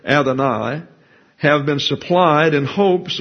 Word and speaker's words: Adonai: They prefer, as we'll note here, the word Adonai, or Adonai is the proper pronunciation.